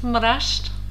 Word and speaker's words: Mraz: Na, ich würde gerne mal, wie heißt, wie spricht man es richtig aus? Das äh, ähm, Mraz, Mrazd, M-R-A-Z? – Mraz, ja.